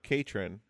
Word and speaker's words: Katrin: So speaking of Katrin, rhymes with patron.